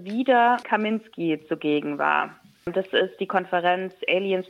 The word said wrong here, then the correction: Alliance: for Responsible, Alliance for Responsible Citizenship, so spricht man das aus.